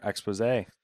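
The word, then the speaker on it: exposé: Firesprite studio staffers say the company took a turn for the worse after becoming a Sony subsidiary, according to a Eurogamer expose. – First off, love that you managed to get the accent on the E in expose.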